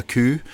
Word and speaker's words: coo: But we do say, especially in the Highlands, we'll say things like a coo, and it's spelled C-O-O. – I was wondering that. Is it like, yeah, K-U-U or C-E-W, but it's...